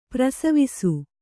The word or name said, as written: ಪ್ರಸವಿಸು